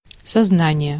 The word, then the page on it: сознание: /sɐˈznanʲɪjə/